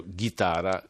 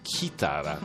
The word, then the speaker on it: chitarra: Perché io da 46 anni dico chitarra, che sarebbe la guitar version of Italian guitar. guitar. Invece si pronuncia chitara e adesso dico sempre chitara, è la mia nuova parola preferita.